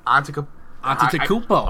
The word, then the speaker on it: Antetokounmpo: but it's Giannis Antetokounmpo. He is Antetokounmpo? – Antetokounmpo.